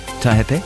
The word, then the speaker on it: Taihape: For Gisborne and Hawke's Bay, scattered showers turning to rain this evening, heavy falls possible north of Wairua, Bay of Plenty, Taupo, Taihape, also for the Wairarapa and Wellington. – You don't say it like that. You've got to use some Maori pronunciation.